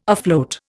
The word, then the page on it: afloat: Indian